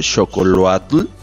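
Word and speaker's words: xocolatl: Y en el caso del chocolate, pues bueno, como les comento, viene del vocablo mexica y se pronuncia casi tal cual como xocoloatl.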